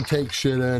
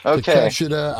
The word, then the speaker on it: Takeshita: anyway, Uh, Mox versus uh, Takeshita. I'm not gonna call him Take Shida anymore, Vito. okay. – Takeshita, I know how to say it.